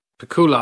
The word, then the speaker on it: Pakula: Pakula, I've been saying. – Whose name until last week I pronounced as Alan J. Pakula. My name is Alan J. Pakula. – No. – But it's difficult for me to say it properly. Pakula. I want to say Pakula. It sounds so much better, Pakula.